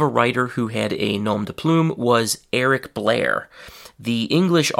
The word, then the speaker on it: nom de plume: That's the French pronunciation, nom de plume, and in English we say nom de plume.